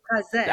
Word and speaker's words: Fraser: Did I say it right? Fraser? – Fraser.